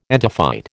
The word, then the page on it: entophyte: American English